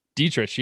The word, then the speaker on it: Dietrich: Derek Dietrich. – Dietrich. Yeah, that's right.